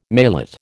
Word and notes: maillot: American English

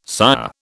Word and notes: sire: British English

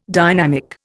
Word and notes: dynamic: Indian

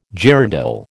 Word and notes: girandole: American English

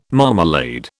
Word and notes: marmalade: British English

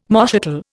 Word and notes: moschatel: Indian

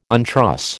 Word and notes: untruss: American English

